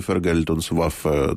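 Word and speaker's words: Vergeltungswaffe: Гітлер мислив це все як зброю відплати, Фергельтонсваффе, це так і називалося, я не впевнений, що я правильно наголос.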